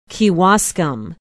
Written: kewaskum

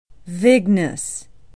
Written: vignes

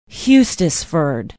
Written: hustisford

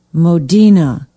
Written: Modena

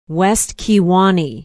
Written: west kewaunee

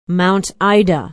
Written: mount ida

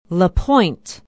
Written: la pointe

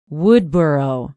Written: woodboro

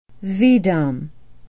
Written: veedum

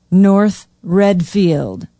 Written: north readfield

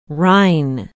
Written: rhine

